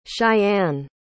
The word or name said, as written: Shianne